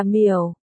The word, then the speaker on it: miểu: Lúc nãy tớ đột nhiên nhớ ba chữ thủy hợp lại đọc là miểu.